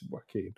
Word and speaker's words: Joaquin: I noticed that, because I see Joaquin in other streams as well, and people say it with a hard J, and I'm like, surely that's not right. Surely it's Joaquin.